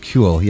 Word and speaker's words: Kule: I believe that's pronounced Cule. Yes, Cule. – Cule. – K-U-L-E.